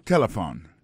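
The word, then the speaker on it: telephone: N'a pas remarqué que l'accent tonique l'a tombé sous premier syllabe là. Si nous besoin de dire bien, c'est téléphone. Alors, ou prononcer ou appuyer plus sous premier syllabe là, qui c'est T.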